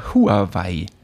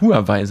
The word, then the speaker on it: Huawei: So, das riecht, also nicht, ich, ich weiß halt, wie es richtig ausgesprochen wird. Und deswegen immer, wenn jemand Huawei sagt, denke ich so, mm, das ist das, so ganz schlimm, ist einfach Huawei.